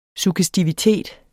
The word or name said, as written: suggestivitet